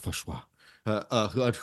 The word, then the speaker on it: Fauci: It says many scientists, including Dr. Fauci, because that's pronounced Fauci,